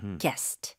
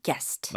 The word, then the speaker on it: guest: 네, 이거는 발음 주의하세요. 이름절로 guest. – Right. – 게스트가 아니고 guest.